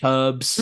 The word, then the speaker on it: herbs: and it's usually between actually how it's pronounced in the States versus how it's pronounced in England. Little things like we say herbs, mm-hmm, and obviously yes. in America they drop their H.